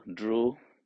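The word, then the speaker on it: dhṛ: So it's almost like you pronounce drew, dhru.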